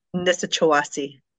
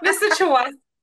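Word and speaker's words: Nisichawayasihk: She's currently a member of Nishewak Cree Nation. Did I say that right? – Nisichawasi Cree Nation. – It's way off. Nisichawasi Cree Nation.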